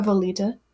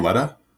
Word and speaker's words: Volita: We haven't talked about Volita. – Okay. – By the way, that's how they say it. – Volita? – Yeah. – Not Valletta? – Well, he's a British narrator.